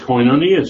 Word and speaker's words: koinonia: That's koinonia service.